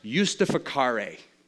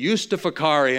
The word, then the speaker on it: justificare: Latin, it translates as a word called, that's pronounced justificare. In Latin, that word would be justificare,